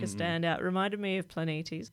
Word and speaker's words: Planetes: I'm glad you can actually pronounce that properly, because for years I've been calling it Planetes. – Ah. – But it's actually written with Greek letters and I couldn't do it right.